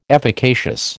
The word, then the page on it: efficacious: American English